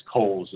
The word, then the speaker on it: Cowles: So, Bill, you had an interview with Michael Coles, and I know it looks like it's spelled Cowles, but it's Coles is the way he pronounces it.